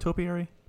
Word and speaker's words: topiary: Well, again, it's like the aesthetic, the atmosphere of just the green of the topiary animals. Am I saying that right, topiary? It's topiary. – Yeah, right? – Topiary, yeah.